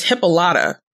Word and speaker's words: Hippolyta: And in this episode, everyone pronounced her name as Hippolyta. So maybe we've been pronouncing it wrong.